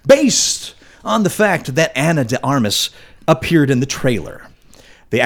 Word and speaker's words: Ana de Armas: A couple of fans of actress Ana de Armas. Is that how you say it? Armas? – Yeah.